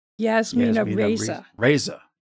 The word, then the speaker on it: Yasmina Reza: Yasmina Reza. Am I saying that right? Reza? – Yasmina Reza.